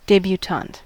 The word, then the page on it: debutante: /ˌdɛbjuːˈtɑːnt/; /ˌdeɪbjuːˈtɑːnt/